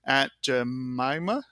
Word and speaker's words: Aunt Jemima: For example, when I'm looking at the Aunt Jemima, did I say that right? – Yeah.